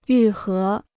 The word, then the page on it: 愈合: yù hé